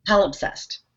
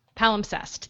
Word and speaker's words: Palimpsest: Rich says, and this is where I'm going to have difficulty with the whimsical titles, reading Palimpsest? Say it again? – Palimpsest. – Palimpsest.